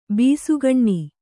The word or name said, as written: ಬಸಕ್ಕನೆ